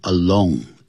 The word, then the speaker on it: alone: 你不用 by herself, 在这边你也可以用 alone, A-L-O-N-E, Alone, 不要念成 along, alone. Along 是 A-L-O-N-G.